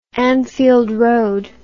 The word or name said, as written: Anfield Road